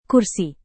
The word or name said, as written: kursi